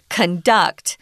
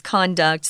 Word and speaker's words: conduct: Um, I wanted to mention uh, this word conduct or conduct. It makes a big difference the way you pronounce it, guys. Some of our words like transfer, transfer, those don't really matter. This one matters. So if it's the verb, it has to be conduct. So the emphasis is on duct, conduct, uh, whereas the noun is conduct.